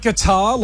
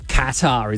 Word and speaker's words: Qatar: Is it guitar? Like a guitar? – Qatar, isn't it? – Correct. – Oh, yeah. Qatar.